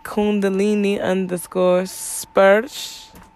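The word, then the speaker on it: Kundalini Sparsa: So Kundalini _ Sparsa, phonetically, if you want to look that up, when you go look that up, whilst you look that up, Kundalini Spursh, that's how it's said, goddess touch.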